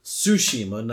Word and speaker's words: Tsushima: And that what I said last episode was I was most excited for Ghost of Tsushima, not Tsushima. I kept saying it wrong, I guess.